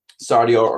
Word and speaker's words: Sardior: Sardior, according to the internet, is pronounced Sardior, and was the dragon god of night, psionics, and secrets, and he was also the master of the gem dragons.